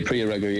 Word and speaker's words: Priya Ragu: priya ragu i don't know if i'm pronouncing it correctly yeah priya ragu yes she's mm.